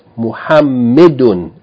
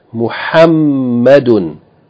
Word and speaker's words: Muhammadun: And this name, famous name, right, is commonly pronounced wrongly as Muhammadun with kasra. That's incorrect. It's not Muhammi, it's Muhamma with fatta. Muhammadun with tanween.